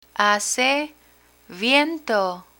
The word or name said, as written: hace viento